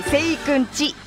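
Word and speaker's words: せいくん家: ゴールデンウィークのラーメン機構、石川町の白川中華そば、はい、せいくんち、家と書いてちと読みます、せいくんちに行ってきたよって、せいくんち特製ラーメン醤油味を食べたスープの一口目でどぎもが抜かれました。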